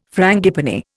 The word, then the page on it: frangipani: Indian